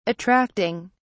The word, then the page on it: attracting: British English